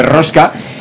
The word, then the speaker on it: rosca: de ruesca, de, rosca, no de ruesca, sino de rosca una vuelta de rosca, ahora lo he dicho bien, y, hacer incluso la traducción con gestos, eh, para, también sordomudos.